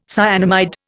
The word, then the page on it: cyanamide: Indian